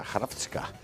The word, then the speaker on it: Hrvatska: Es como se escribe Croacia en croata, Hrvatska.